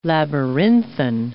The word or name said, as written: labyrinthine